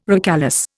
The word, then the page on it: recalesce: Indian